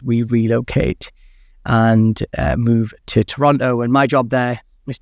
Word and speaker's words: Toronto: or Toronto as the locals say.